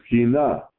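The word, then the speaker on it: kinah (קנאה): In Hebrew is kinah.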